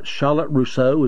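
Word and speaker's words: Charlotte Russe: But I know I pronounced this one wrong the first time. You said Charlotte Rousseau, is that right? – Yes. – That's how you pronounce it, okay.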